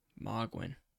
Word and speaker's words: Mohgwyn: How do you spell that? – M-O-H-G-W-Y-N. Mogwin.